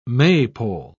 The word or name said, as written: maypole